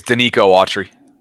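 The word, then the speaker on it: Denico Autry: I think it's Danico Autry. – Okay. I knew it was Danico. I didn't know about Autry.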